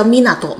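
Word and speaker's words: 湊: 其实你会发现麦野凑这个凑日语读音不是叫 Minato 吗？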